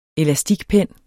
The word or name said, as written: elastikpen